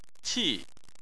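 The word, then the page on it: qi: tone 4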